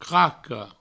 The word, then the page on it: craca: Provençal; craco